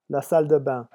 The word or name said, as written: la salle de bain